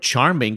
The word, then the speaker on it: Charmin: It's not from the word charming because there's nothing charming about toilet paper. – Charmin.